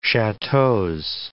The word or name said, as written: chateaux